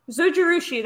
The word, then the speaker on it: Zojirushi: It's like Zojirushi. – Zojirushi, that's it.